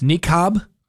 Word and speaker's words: niqab: how do you say this? Niqab? N-I-Q-A-B? – I think that's right.